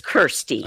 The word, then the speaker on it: Kirstie: So tell us a little bit about, is it Kirsty or Christy? It looks like it's Kirsty. – Oh, it's yep. spelled, am I crazy or is it spelled Christy? – No, it's spelled K-I-R-S-T-I-E, although I may have spelled it wrong in an email to you.